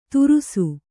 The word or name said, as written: ದುರುಸು